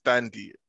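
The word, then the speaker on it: Thandie: I think it's pronounced Thandy, but yeah, Thandy. Yeah. – I think it's pronounced that way.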